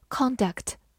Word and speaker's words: conduct: 但是当它做名词的时候，重音放在了前面，读作 conduct。